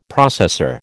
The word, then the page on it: processor: American English